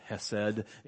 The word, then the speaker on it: hesed: There's this wonderful Hebrew word, I will pronounce it hesed, H-E-S-E-D, and that's what appears in both these instances of God's love towards David here.